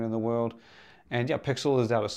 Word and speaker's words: PXL: But it is spelled PXL, pronounced Pixel.